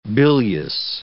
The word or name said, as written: bilious